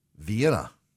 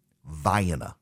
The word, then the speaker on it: Vienna: You would think, undoubtedly, dear listener, that it is pronounced Vienna. You would be wrong. It is pronounced Vienna. Vienna. There is also a town even further south, C-A-I-R-O, spelled exactly the same way as the capital of Egypt.